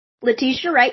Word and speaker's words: Letitia Wright: Letitia Wright, you were getting it.